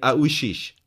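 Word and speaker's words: Aouchiche: Adil Aushish. Als erstes höre ich sofort Hashish, aber es ist Aushish.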